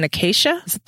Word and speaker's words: acacia: There's definitely blood in it, as well as resin and acacia. Did I say that right? Acacia? – Mm-hmm.